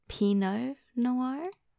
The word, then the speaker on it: Pinot Noir: Okay, it's definitely not peanut. Noir. Pinot Noir? Was a pronunciation. Pinot Noir. Pinot Noir.